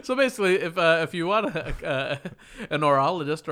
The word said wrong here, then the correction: horologist: I keep on saying orologist, but it's horologist.